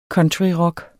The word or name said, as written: COUNTRYROCK